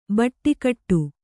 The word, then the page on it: ಬಟ್ಟಿಕಟ್ಟು: baṭṭikaṭṭu